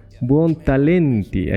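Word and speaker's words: Buontalenti: La cosa es que dice que el italiano Bernardo Buolanten... Pucha, ¿cómo se pronuncia? Bernardo, ya eso está fácil. Buon Talenti, ahí está, Buon, em, que era arquitecto, escenógrafo e ingeniero militar.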